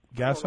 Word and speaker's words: Gaiso: The first question comes in from Mike Gaiso, G-A-I-S-O. Is that how you pronounce it, Gaiso? Gaiso? – Oh, no, I think you did a good job.